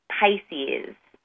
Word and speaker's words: Pysis: My product is Pisces. It's spelled P-Y-S-I-S.